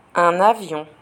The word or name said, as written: un avion